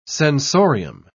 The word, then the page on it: sensorium: British English